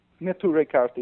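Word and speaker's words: Neturei Karta: Now, Netarai Karta, am I saying that correctly? – Netarai Karta, yes. – Netarai Karta.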